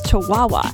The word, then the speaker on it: Chihuahua: was I saying that right? And he says, no, it's Chihuahua. And I was like, you mean you let me say Chihuahua to your family, like, six times, and nobody told me anything?